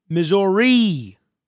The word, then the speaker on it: Missouri: The name of our state is Missouri. It's pronounced Missouri.